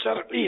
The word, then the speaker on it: شرعی: شرعی تلفظ شرعی ہے, شرعی یہ اہل علم بھی بول رہے ہوتے, شرعی را پر زبر نہیں ہے, را ساکن ہے, اس پر جزم ہے.